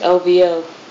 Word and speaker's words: OVO: Is that how you say it? OVO? OVO? – I think it's OVO. – Oh, you have to say all the letters? – I think so.